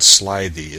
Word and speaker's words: slithy: The I in Slithy is long, as in writhe, and Toves is pronounced so as to rhyme with groves.